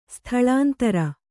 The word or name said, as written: ಸ್ಥಳಾಂತರ